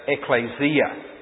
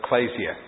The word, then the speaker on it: ecclesia: as the Americans say, ecclesia. But I studied Greek in Australia, and so it's ecclesia, okay? So if you like listening to the Americans, it's ecclesia.